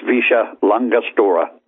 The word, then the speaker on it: Villa La Angostura: Yeah, they pronounce it Visha. I don't know how LL comes out S's, but it's Visha La Angostura.